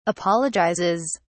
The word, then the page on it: apologizes: British English